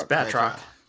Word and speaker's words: Batroc: Bartrock, was that how you pronounce his name? Like Bartrock? It's Batroc.